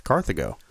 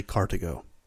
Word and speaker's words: Carthago: The first of which is he dusted off his high school Latin and chastised me for pronouncing Carthago as Carthago. It's actually Carthago. The H is silent, which he was kind enough to inform me about that.